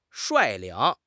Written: 率领

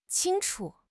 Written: qing1 chu5

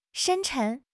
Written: 深沉

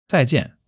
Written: zai4 jian4

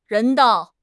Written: 人道

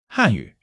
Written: 汉语

